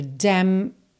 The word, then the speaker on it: damned: Porque a gente tem damned, que é D-A-M-N-E-D. Mas, praticamente, o N e o E, você vai esquecer deles. Então, você vai pro dam no M, né?